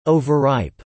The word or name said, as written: overripe